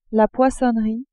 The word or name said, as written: poissonnerie